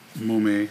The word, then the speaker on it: Mumme: But it's insane that it's about mainly about one guy, Hal Mumme. I think it's how it's pronounced, Mumme, M-U-M-M-E, Hal Mumme.